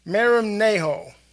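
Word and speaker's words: Merim Naho (μεριμνάω): Merim Naho, and you see the mark at the H. M-E-R-I-M-N-A-H-O, Merim Naho, means to be anxious.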